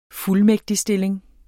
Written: fuldmægtigstilling

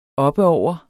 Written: oppeover

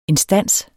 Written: instans

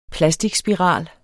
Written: plasticspiral

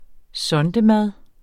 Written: sondemad

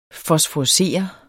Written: fosforescere

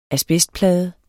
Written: asbestplade

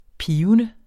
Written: pibende

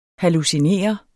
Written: hallucinerende

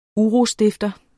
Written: UROSTIFTERNES

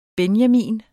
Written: benjamin

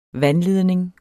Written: vandledning

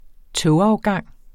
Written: togafgang

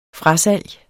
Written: frasalg

